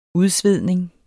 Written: udsvedning

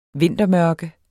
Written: vintermørke

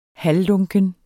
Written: halvlunken

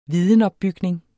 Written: vidensopbygning

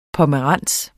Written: pomerans

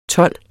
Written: told

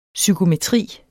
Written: psykometri

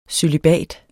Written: cølibat

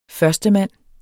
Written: førstemand

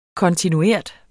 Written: kontinuert

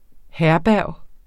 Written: HERBERGERNES

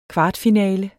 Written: kvartfinalen

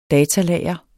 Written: DATALAGRE

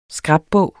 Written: scrapbog

